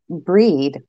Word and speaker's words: Brigid: But Well, I just looked it up, and apparently I pronounced it wrong. They said it's pronounced breed or breeze.